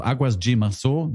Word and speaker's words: Águas de Março: Also wenn man Águas, also A-G-U-A-S de Março schreibt. – Mhm. – Also Águas de Março, man G, also D-E wird G ausgesprochen.